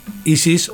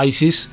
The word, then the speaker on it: Isis: Estamos hablando de una banda llamada Isis o Isis, como quieras pronunciarlo,